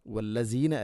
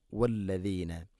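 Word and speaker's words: وَالَّذِينَ: വല്ലസീന അല്ല, വല്ലദീന. വല്ലദീന ദാല് നാവിൻ്റെ അറ്റം മുൻപല്ലുകളുടെ ഇരുനരകൾക്കിടയിലൂടെ അല്പം പുറത്തോട്ട് വരണം, അതാണ് ദ.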